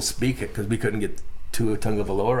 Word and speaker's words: Tua Tagovailoa: What about Tua Tagovailoa? – Oh, my gosh, you got the name right.